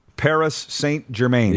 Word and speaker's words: Paris Saint-Germain: Did I say that right? – Which one? – Paris Saint-Germain. – Yeah. – Thank you. – I think that was pretty good.